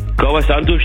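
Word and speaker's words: Kauã Santos: Kauer Santos, den hast du gut ausgesprochen. Der ist super.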